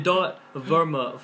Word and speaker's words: Verma: It's Rohit, so it's R-O-H-I-T with another T. So two T's. And then .Verma. Fuck. V-E-R-M-A with another A.